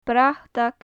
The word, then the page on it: bratag: gaelic